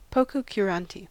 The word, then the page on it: pococurante: American English